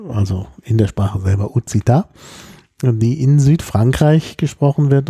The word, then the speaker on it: occitan: Also stand dort äh, das Wort, also richtig ausgesprochen, Uzita, also auf Deutsch würde man vielleicht sagen Occitan, äh, also Occitan geschrieben, äh, O-C-C-I-T-A-N.